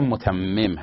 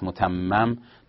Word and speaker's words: متمم: که تلفظ درستش هم متمم هست، متمم نباید بگیم.